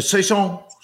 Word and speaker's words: Saison: How do you pronounce Saison. – It? Saison. Saison.